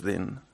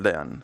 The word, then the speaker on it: Dean: Yo soy español, Ignacio Dean, si lo quieres pronunciar en inglés, pues es Din. – ¿Tú cómo lo pronuncias habitualmente? – Yo en español, Dean, ¿ah, sí? – Ignacio Dean, sí.